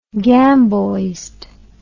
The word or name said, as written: gamboised